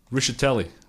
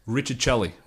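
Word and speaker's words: Rischitelli: Sean Lemons and, can I say it like Pete would say, Michael Ricciatelli. Pete used to call him Richard Chelly.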